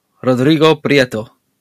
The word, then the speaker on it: Rodrigo Prieto: Killers of the Flower Moon, Rodrigo Prieto.